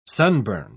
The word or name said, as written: sunburned